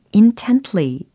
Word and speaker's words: intently: intently 하시지 마시고 intently 이렇게 tly 예, 끝부분 tly 이렇게 처리해 주시면 완벽하게 깔끔하게 예, 연습이 됩니다.